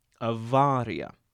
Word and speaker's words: avaria: You'll notice that I'm pronouncing the Latinized form of awar with a V sound, avaria. Now, that word, avaria, is spelled A-V-A-R-I-A, but in classical Latin phonology, which is the Latin phonology most frequently taught and learned, consonantal V is pronounced with a W sound, akin to the sound of English W.